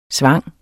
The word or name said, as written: svang